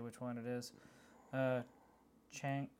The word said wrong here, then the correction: Shang-Chi: gonna butcher that. – Shang-Chi 2. Yeah, Shang-Chi.